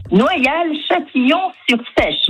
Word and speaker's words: Noyal-Châtillon-sur-Seiche: Noyal-Châtillon-sur-Seiche.